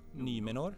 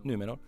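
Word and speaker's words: Númenor: Från det mörkaste djupen av Dimmabergen och de majestätiska skogarna i Alvernans huvudstad Lindon till den förbluffande öriket i, nu ska jag uttala det, Numenor. Numenor. Det är en sån här U med plupp på. Numenor.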